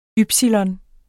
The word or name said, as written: ypsilon